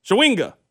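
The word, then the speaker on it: Chawinga: Shawinga.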